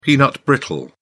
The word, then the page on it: peanut brittle: British English